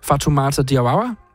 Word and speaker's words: Fatoumata Diawara: Fatoumata Diawara.